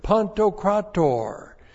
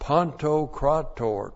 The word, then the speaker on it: Pantokrator: Kurios is the Lord, and now we have a real special word, pantokrator, to describe the Lord. Pantokrator. P-A-N-T-O-K-R-A-T, long O-R. Pantokrator.